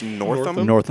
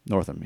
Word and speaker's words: Northam: Did I say that right? Jeremy Northam. – I think it's Northam. – Northam, yeah.